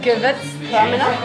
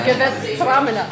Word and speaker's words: Gewürztraminer: Gewürztraminer? Am I saying it right? – Perfect. – Yes. I teach my students. They're like, how do you say this word? I'm like, Gewürztraminer.